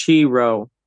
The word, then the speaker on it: Chi Rho: It's usually pronounced Chi Rho, C-H-I-R, I think R-H-O.